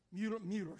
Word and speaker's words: Mueller: Mueller, excuse me, is how you pronounce it.